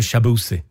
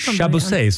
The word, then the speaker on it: Shabusi: Om man ska nämna en artist som verkligen har levererat i år, då är det Shabusi. – Ja, han är riktigt bra. Och tänk att du inte ens kunde uttala hans namn. – Chabosey sa jag i början.